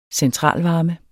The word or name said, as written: centralvarme